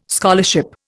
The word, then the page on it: scholarship: Indian